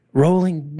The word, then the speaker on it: Rowling: Rowling was rolling. Actually rolling, dang it.